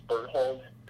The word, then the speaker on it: Berthold: My name is Thomas Berthold, and it's spelled B-E-R-T-H-O-L-D.